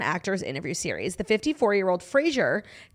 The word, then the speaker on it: Fraser: So he says, instead of a similar pronunciation similar to the 90s sitcom Frasier, Frasier says his name actually rhymes with razor. So it's Fraser.